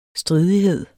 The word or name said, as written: stridighed